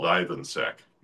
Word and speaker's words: Ivansek: My name's pronounced as easily as it's spelled, Ivansek.